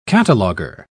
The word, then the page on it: cataloger: British English